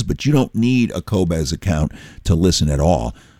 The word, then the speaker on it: Qobuz: It's Q-O-B-U-Z, pronounced Qobuz, and they are the most artist-friendly of all digital providers.